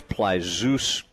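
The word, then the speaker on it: Plitzuweit: And the middle part of that is zoo, so it's Plazus.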